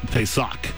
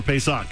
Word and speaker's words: Pesach: And sixth is Pesach. P-E-S-A-C-H.